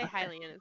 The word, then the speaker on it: Hylian: You are actually a Hylian. Did I say that right? Hylian? – Yes, I say Hylian as well.